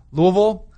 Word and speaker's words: Louisville: Louisville. You like that? You got the correct, like, that's how they say it in Louisville.